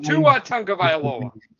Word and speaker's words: Tua Tagovailoa: Tunga Tagovailoa. Did I say that right? – No. Combined. – Tua Tagovailoa.